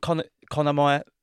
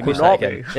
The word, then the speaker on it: Konami: And haven't Konami... Sorry, I'm saying that wrong, don't I? – Konami, okay. Konami. Yeah, if we go back again.